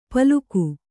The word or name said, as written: ಪಲಕು